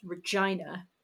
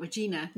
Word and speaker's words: Regina: Which is why I know it is pronounced Regina. – Not Regina. – Not George. – Regina. George, exactly. I think it's pronounced that way.